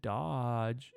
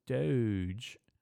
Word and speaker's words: Doge: Dude, that fucking spick said it wrong, dog. He said Dodge. It's Doge.